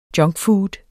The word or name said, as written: junkfood